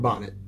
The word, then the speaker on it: bonnet: Witnesses described her as a lady with long dark hair, wearing a white nightgown and a boutonnet. I want to say that's how it's pronounced, or a bonnet. However you want to say it. – Bonnet, okay.